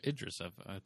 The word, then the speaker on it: Idris Elba: No, I was going to ask you because I cut you off with the Idris Alba, Idris, Idris, Am I saying that right? Idris, Idris, Elba.